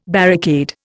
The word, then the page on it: barricade: Indian